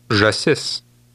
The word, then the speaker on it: Žasis: Žasis. – Did you remember to stress the last part of that word?